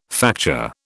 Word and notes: facture: British English

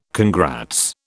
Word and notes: congrats: British English

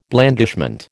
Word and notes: blandishment: American English

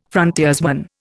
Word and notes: frontiersman: Indian

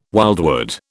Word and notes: wildwood: British English

